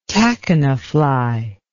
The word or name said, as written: tachina fly